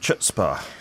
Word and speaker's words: chutzpah: She's got some chutzpah. – Chutzpah is the pronunciation.